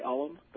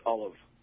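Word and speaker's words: Ollam: You're pronouncing it traditionally in the sense that most people do say Olive, but it's actually pronounced Olive.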